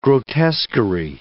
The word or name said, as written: grotesquery